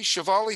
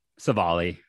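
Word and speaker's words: Civale: And I really think, and if you look at, please tell me how you pronounce, is it Savali? Shivali? How do you pronounce his name? – Savali. – Savali.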